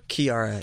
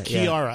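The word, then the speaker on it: Kiiara: Her name, is it pronounced Kiara? – Kiara. – Kiara. – Yeah.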